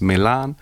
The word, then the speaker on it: Melane: Her name is spelled MELANE, so you find her on all social media platforms, Milan.